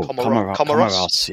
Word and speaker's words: Comoros: Oh, Comoros, yes.